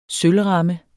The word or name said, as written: sølvramme